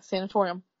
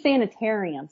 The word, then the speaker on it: Sanatorium: I think I'm saying it right. Steve says I say it wrong. The book that he picked is The Sanatorium. – Yeah, Sanatorium. – Yeah, that's what she said. She said I was right. You're saying sanitarium, Steve.